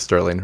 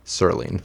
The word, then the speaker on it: Serling: Not Sterling. Rod Serling.